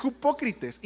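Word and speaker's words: hipócrites (ὑποκριτής): Ahí lo puse en griego por si alguien sabe leer griego, pero como se pronuncia es hipócrites y era alguien que actuaba en el teatro.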